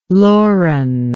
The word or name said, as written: Lauren